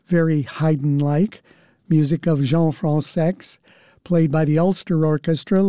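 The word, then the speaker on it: Françaix: He spells it F-R-A-N-C-A-I-X. It is commonly pronounced Francais, but the composer himself and his close circle of friends pronounced it Francex. He said that he was tired of correcting people, so if they want to pronounce it Francais, that's fine with him, but he prefers Francex.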